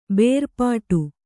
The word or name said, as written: ಬೇರ್ಪಡು